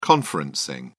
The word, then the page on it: conferencing: British English